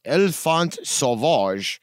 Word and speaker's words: Enfant Sauvage: But I believe the proper pronunciation of the track is Enfant Sauvage, which is French for the wild child, and is the fifth studio album, self-entitled, by the band Gojira, released on June 26th, 2012, via Roadrunner Records.